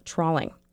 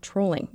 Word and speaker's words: trolling: And in another story aired on Monday, we mispronounced trolling as trawling. That story was about trolling, which is a different method of fishing than trawling.